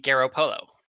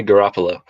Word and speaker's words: Garoppolo: Say that whole name. – Jimmy Garoppolo. – It was closer. It's Jimmy Garoppolo.